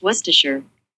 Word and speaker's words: Worcestershire: How do you pronounce Worcestershire? – That's pronounced Worcestershire. – Worcestershire. That's right.